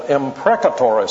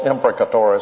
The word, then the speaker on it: imprecatory: I sometimes hear people call them imprecatory psalms. Well, they're still imprecatory psalms, even though they mispronounce the word, but it's possible to understand it the other way. Both of these pronunciations are acceptable, but an imprecatory psalm is a psalm which deals with curses.